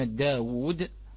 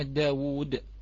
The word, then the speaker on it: داود: على سبيل المثال أن كلمة تلوون أو كلمة داود الآن إخوتي الأحبة تلفظ بكم واو؟ كلمة داود تلفظ بكم واو؟